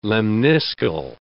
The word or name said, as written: lemniscal